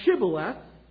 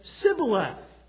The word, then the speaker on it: Shibboleth: If he said no, then they would say to him, 'Say now Shibboleth.' But he said, 'Sibboleth,' for he could not pronounce it correctly.